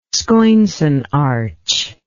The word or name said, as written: scoinsonarch